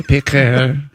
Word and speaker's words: Piguet: He set his sights on a rare Audemars Piguet watch. – Those are very nice. – P-I-Q-U-E-T, Piguet.